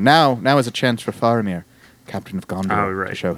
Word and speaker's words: Faramir: I'm pretty sure it's pronounced Faramir.